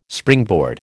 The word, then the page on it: springboard: American English